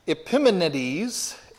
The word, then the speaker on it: Epimenides: He quotes Epimenides, that's how you pronounce it.